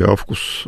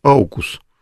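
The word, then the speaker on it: AUKUS: Они не зря начали создавать там военный блок Окус или Авкус. Его неправильно произносят а, Аукус. Ну, я говорю, у нас очень сильно упало образование даже у политиков, потому что АУ, мы же не говорим Аугуст, и Аурелий.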